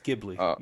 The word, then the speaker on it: Ghibli: It's Ghibli. You said it right.